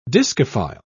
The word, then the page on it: discophile: British English